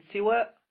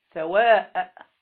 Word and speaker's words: سوى: قال أو كان بغير وسوى، قال بالكسر يعني بكسر السين، كلمة سوى تنطق بكسر السين، والضم يعني السوى، سوى وإيه؟ وسوى. مقصورا يعني لو جعلت ما بعد الواو مقصورة، ألف مقصورة، سوى زي ليلى وسلوى. تمام؟ وبالفتح ممدودا، لو أنت جعلتها ممدودة زي أسماء، صحراء، علياء، فقلت إيه؟ سواء.